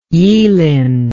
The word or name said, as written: yealing